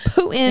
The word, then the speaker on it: Putin: Rhymes with tootin'. – Yeah. We're paying Putin.